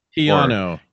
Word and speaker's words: Keanu: Keanu.